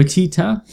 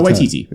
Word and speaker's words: Waititi: Taika Waititi. – Waititi. – I think. – Waitita? Is it Waititi? – No, Waititi.